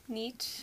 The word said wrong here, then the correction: niche: Well, you want to say niche? – Niche.